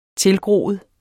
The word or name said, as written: tilgroet